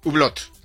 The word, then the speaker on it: Hublot: Hublot.